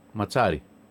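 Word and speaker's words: Mazzarri: 3-5-2 θα παίξει με τον καινούριο προπονητή, τον Βάλτερ Ματσάρη ή Ματζάρη τελικά? Μας φάγατε. Ματσάρη. – Όπως ένα μιλάει ιταλικά, θα έχει τη σωστή προφορά. – Με Ματζάρη. Δύο ζήτα. Με δύο ζήτα είναι ματσά, ζήτα.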